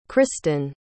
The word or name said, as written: christan